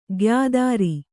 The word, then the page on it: ಗಾಯತ್ರಿ: gāyatri